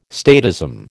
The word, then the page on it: statism: American English